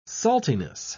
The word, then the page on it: saltiness: British English